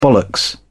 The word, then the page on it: bollocks: British English